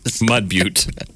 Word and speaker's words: Mud Butte: I don't know if they have a mayor, but it's Mud Butt, South Dakota. – Shut up. – Yeah. – Mud Butte. – I was going to say. – I pronounce it butt. – B-U-T-T-E.